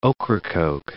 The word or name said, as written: Ocracoke Island